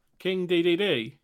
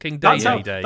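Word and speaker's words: Dedede: King DDD. – Okay, that is how you pronounce it. It's not King Day.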